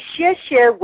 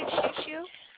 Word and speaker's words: xie xie: You can say xie xie, which is thank you in Mandarin Chinese. – Oh, beautiful. How do you say xie xie? – Xie xie.